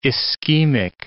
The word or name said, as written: ischemic